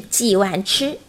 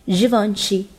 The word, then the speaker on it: Givenchy: 不是 G one 吃，也不是 Gay one tree，而应该读成 Givenchy。同时呢，重音呢会放在最后的一个音节上。